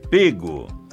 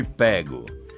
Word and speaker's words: pego: Sim, está certa, mas com a pronúncia fechada, pego. Evite a pronúncia aberta, ele foi pego. Pego, aliás, significa pântano.